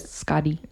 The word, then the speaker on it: Skadi: But I think it's pronounced as a D. – Oh, so it almost kind of sounds like our word for like skate. I don't know, but Skadi.